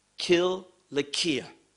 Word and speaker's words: Cilicia: Now, I realize many want to pronounce it with an S sound because it's written in C, but in the Greek language, it is Cilicia.